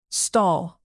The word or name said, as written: stall